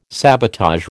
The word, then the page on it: sabotage: American English